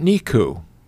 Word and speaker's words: Niku: How about Niku? Did I pronounce it right? N-I-K-U. – That's how I would pronounce it. – N-I-K-U, kind of this weird high inclination object in the outer solar system.